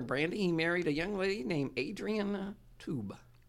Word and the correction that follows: Taub: T-A-U-B. How would you pronounce it? – T-A-U-B? Uh-huh. Taub? – All right. Adrienne Taub.